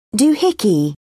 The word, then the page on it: doohickey: British English